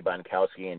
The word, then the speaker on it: Bonkowski: It's at Jerry, J-E-R-R-Y. And the last name is spelled as it sounds, as I always like to say, B as in boy, O, N as in Nancy, K-O-W-S-K-I, again, at Jerry Bonkowski, and you can get me on Twitter.